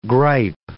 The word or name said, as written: gripe